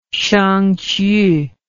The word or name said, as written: shangqiu